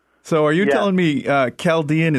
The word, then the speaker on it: Chaldean: Yeah, Chaldean is how we pronounce it.